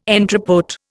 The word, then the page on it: entrepôt: Indian